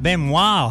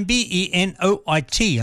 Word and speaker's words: Benoit: American bluesman there, Tab Benoit. I think that's how you say his last name, Benoit, and I believe the T is silent.